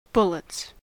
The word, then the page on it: bullets: American English